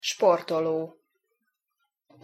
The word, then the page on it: sportolo: Hungarian